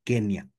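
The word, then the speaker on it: Kenya: En inglés le dieron, para pronunciar el nombre de Kenia, realmente le pusieron una N y griega, y ese dígrafo tendría que pronunciarse como una ñ. Pero en español, pues lo copiamos N y griega y dijimos, pues es una NI y decimos Kenia.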